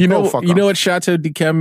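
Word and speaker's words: Yquem: Here's a wine that I can't pronounce properly that is well known. Because there's a Y in front of it. Dechem. – Chateau Dechem. Dechem. – It's very hard to pronounce. – Can't do it either. What is that combination? Y-Q-E-M?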